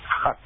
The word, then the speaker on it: khat: it's called KHAT, they pronounce it hat.